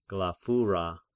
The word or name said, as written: Glaphyra